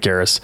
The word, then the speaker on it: Garrus: Garrus.